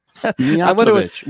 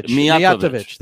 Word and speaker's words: Mijatovic: Mijatovic. We got to get this right. – Mijatovic. Thank you. Mijatovic. – Third syllable from the end.